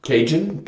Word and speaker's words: Cajun: Also, weil der Hank Williams, der hat ein Lied, wie wird es ausgesprochen? – Cajun.